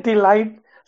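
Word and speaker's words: de Ligt: It's De Legit? De Lid? – Oh, Delight? Delight? – Delight. Sounds like the 90s.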